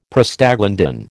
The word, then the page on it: Prostaglandin: American English